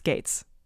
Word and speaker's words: Goetz: His last name is spelled G-O-E-T-Z, but apparently is pronounced Gates.